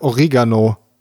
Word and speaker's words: Oregano: Das heißt Oregano. Laut Duden.